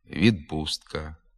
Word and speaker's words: відпустка: У префіксах і прийменниках «об», «від», «над», «перед», «під», «між» звуки «б», «д», «ж» перед глухими приголосними зберігають свою дзвінкість. Наприклад, «обставини», «обслуговування», «відправити», «відпустка», «відтовариша», «надпити», «надкручею», «передплата», «перед труднощами», «підписати», «під пахвою», «міжпланетний», «між сусідами».